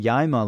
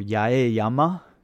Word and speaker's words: Yaeyama: And then you'd have the Yaima language, or Yaeyama, to use the whole, like, standard Japanese pronunciation of that.